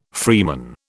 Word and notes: freeman: British English

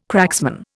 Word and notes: cracksman: Indian

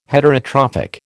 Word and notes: Heterotrophic: American English

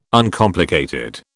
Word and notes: Uncomplicated: British English

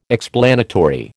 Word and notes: explanatory: American English

